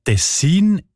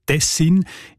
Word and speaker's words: Tessin: Die Variante mit der Betonung auf der ersten Silbe und mit kurzem I, also Tessin, ist seltener.